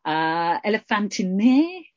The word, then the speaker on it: Elephantine: Elephantine is the proper Greek pronunciation.